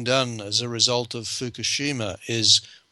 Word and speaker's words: Fukushima: We have this catastrophe at Fukushima. Am I pronouncing this right? Fukushima? – Yes, Fukushima. Mm-hmm.